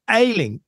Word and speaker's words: Ayling: Now, AYLING, am I pronouncing it correctly? – Yes, that's correct.